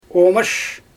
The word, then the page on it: oomash: Xyzyl